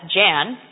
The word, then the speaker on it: Jan: But when he came to this country, they looked at J-A-N, and they said, "Well, we know how to pronounce that. That's Jan." So everybody calls him Jan now. But it was Jan.